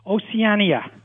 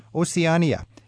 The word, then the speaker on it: Oceania: Is it Oceania? – Oceania. – Oceania. – Yeah, and there's an I there at the end of the word.